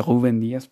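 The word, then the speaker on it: Rubén Dias: creo que se dice Rubén Díaz, porque la R en portugués se dice así.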